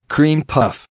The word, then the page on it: cream puff: American English